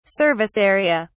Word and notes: service area: British English

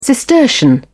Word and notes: cistercian: British English

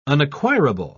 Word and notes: unacquirable: British English

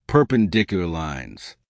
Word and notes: perpendicular lines: American English

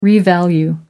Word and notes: revalue: American English

